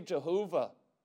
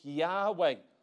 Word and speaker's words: Yahweh: Well, yeah, you can say Jehovah, But you know what? It's only four letters, more correctly pronounced Yahweh.